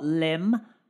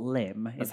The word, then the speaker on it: Lympne: I think it's Port Lyme. How you meant to pronounce it.